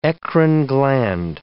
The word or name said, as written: eccrine gland